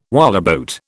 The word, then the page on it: whaleboat: British English